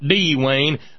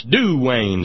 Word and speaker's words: Duane: That's Duane, not D-Wayne. That's Duane. Say it right, just like he said, do, Lord, oh, do remember me.